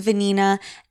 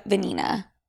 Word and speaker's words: Vanina: And yes, my middle name is Vanina. And yes, it is very similar to the word vagina. It is a very Argentine name. And yes, it rhymes with my first name, Sophia Vanini. Sophia Vanina.